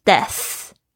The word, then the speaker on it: death: 那 die 的名词呢，就是 death，特别注意一下你们 th 的发音好吗？death 是有轻轻咬住舌尖，不要咬得太重哈。